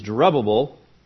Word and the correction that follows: Jerubbaal: and it means discomforter of Baal. Jerubbaal, I guess would be a better way to pronounce it.